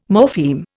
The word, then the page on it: morpheme: Indian